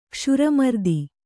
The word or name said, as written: ಕ್ಷುರಮರ್ದಿ